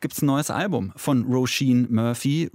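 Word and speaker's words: Róisín: Well, first of all, it's Roisin Machine, because that's my name.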